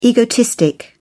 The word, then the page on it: egotistic: British English